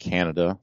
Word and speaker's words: Kaneda: I don't like Kaneda, or how do you say his name? – It's actually pronounced Canada, the places you live.